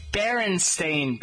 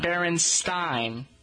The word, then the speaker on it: Berenstain: 1923, Jan Bernstein, American author and illustrator. Jan Berenstain. It's Berenstain, Berenstain. – Yeah. – Not Berenstain.